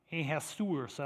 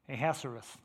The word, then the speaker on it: Ahasuerus: The Persian king, Ahasuerus. I don't think I'm pronouncing it quite right. Ahasuerus. I think that's better.